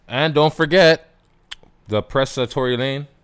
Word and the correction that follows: Tory Lanez: Tory Lane. I keep saying it, man. – Tory Lane. – Was it the right? I said it right the first time? – Tory Lanez. – Tory Lanez? All right.